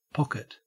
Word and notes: pocket: Australian English

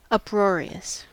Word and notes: uproarious: American English

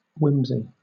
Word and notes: whimsy: British English

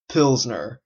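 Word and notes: pilsner: Canadian English

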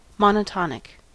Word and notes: monotonic: American English

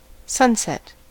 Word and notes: sunset: American English

